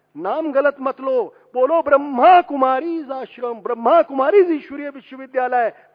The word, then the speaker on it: ब्रह्मा कुमारी: इसलिए इनको कहा जाता है राजयोगिनी तपस्वी मूर्ति बहने ब्रह्मा कुमारीज और ब्रह्मा कुमार। एक बात और कह दूं सभी संतों से, यहां कुछ लोगों ने उच्चारण किया है ब्रह्म कुमार, ब्रह्म कुमारी, ब्रह्म कुमारी आश्रम नहीं है, ये ब्रह्मा कुमारी है।